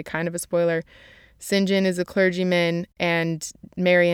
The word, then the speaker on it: St. John: Now, St. John, his name is written St. John, like S-T period John, but it's pronounced St. John, and I have no idea why, but that's just how it's pronounced.